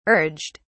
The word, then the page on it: urged: British English